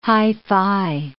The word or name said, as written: hi-fi